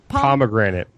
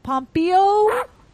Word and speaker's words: Pompeo: Pomegranate. – Pompeo.